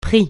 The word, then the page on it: prix: francais